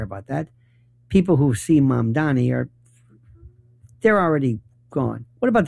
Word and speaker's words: Mamdani: It's Mom, like Mom and Dad, Donnie, like Donnie Osmond, Mom Donnie. But you hear Mandani and Mandani.